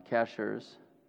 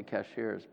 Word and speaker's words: Cashiers: Todd's come all the way from the mountains of North Carolina, where he lives in Cashers. Did I say it right? I always want to say Cashiers, but...